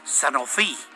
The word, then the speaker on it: Sanofi: Sanofi, es en francés, ¿no? Para pronunciarlo bien como es debido, ¿no? Como lo hacen los franceses.